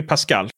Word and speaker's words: pascal: Hur uttalar man det ens? – Det är ju Pascal.